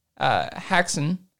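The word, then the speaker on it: Häxan: It's a silent film from 1922, and it's called Haxan, Witchcraft Through the Ages. It's spelled... – Sounds like a board game. – H-A-X-A-N.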